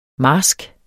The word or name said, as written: marsk